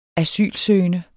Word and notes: asylsøgende: [ -ˌsøːjənə ]; [ -ˌsøˀjənə ]

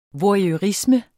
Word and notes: voyeurisme: vwɑjœˈʁismə; vʌjœˈʁismə

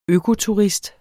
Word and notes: økoturist: ˈøːko-; ˈøko-

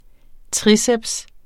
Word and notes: triceps: ˈtʁiˌsεbs; ˈtʁiː-

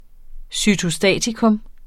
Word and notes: cytostatikum: sytoˈsdæˀtikɔm

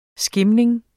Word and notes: skimming: [ ˈsgemeŋ ]

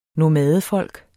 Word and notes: nomadefolk: [ noˈmæːðəˌfʌlˀg ]

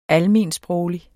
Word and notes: almensproglig: [ ˈalˌmeˀnˈsbʁɔwli ]; [ ˈalˌmeːn- ]

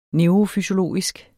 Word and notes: neurofysiologisk: ˈnœwʁofyɕoˌloˀisg; ˈnεwʁofyɕoˌloˀisg

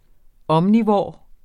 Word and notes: omnivor: ˈʌmniˌvɒˀ; ˈʌmniˌvoˀɐ̯

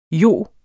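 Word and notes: jo: Danish

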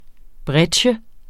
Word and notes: breccie: ˈbʁεdɕə; ˈbʁεgjə

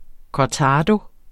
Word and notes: cortado: kɒˈtɑːdo; kɒˈtæːdo; kɒˈtɑːðo

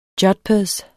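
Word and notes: jodhpurs: Danish

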